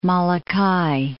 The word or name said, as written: Molokai